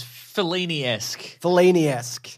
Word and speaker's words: Fellini-esque: Not Fanilski. Is that how you say that? – No, you've written Fanilski. It's Fellini-esque. Yes.